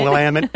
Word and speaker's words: Willamette: Willamette.